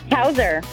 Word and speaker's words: Houser: Houser. – Spell it for me. – H-O-U-S-E-R.